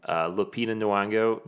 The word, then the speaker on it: Nyong'o: It's Lupita Nyong'o, mm-hmm.